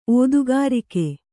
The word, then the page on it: ಓದುಗಾರಿಕೆ: Kannada